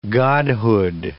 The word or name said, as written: godhood